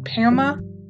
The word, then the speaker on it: PAMA: called PAMA. That's how you say it. It's spelled PAMA.